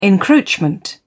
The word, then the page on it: encroachment: British English